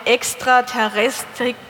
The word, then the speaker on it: Extraterrestrik: Raumfahrtmanagement in der Abteilung Extraterrestrik, habe ich das richtig gesagt? Ja.